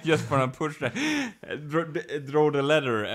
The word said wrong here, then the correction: lever: uh, uh, or lever. – how do you say? – Yeah, leather. Lever. Yeah. not leather.